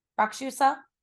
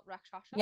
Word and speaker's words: Rakshasa: Rakshusa is the pronunciation that I found online. – I've seen two. Yeah. I've saw Rakshasa. – Yeah. – And Rakshasa. – Yeah. – But we're trying our best. It is in Sanskrit.